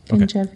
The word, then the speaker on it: Ganjavi: Wrote, how do you say it? Nizami Ganjavi. – Ganjavi. – Okay.